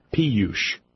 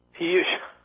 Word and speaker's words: Piyush: Yeah, but how you say his first name is not Bobby. – How do you say it? – Piyush. – Piyush. Okay.